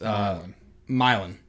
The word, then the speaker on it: Milan: Pearl Cone at Milan. I think I'm saying that right. M-I-L-A-N. – It's Milan. – Milan? Okay. Mm-hmm.